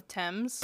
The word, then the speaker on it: Thames: So I'm going to butcher all of these fucking names, and now I still have a complex on pronouncing things wrong because I pronounce the river of, what is it called? River of the Thames. – Thames? – Yeah, the Thames River. – That makes no fucking sense to me.